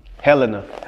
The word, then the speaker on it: Helena: Helena. – Helena. – See, yeah, I Helena. Know fucking Helena, up. Arkansas. – So, Hell Town. Yeah. – So, what goes down in Helena? – Man, it's bad down there, man.